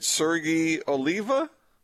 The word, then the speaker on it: Sergi Oliva: Am I pronouncing this right? Sergi Oliva? – That sounds right to me.